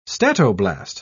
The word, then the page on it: statoblast: British English